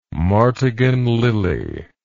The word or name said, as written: martagon lily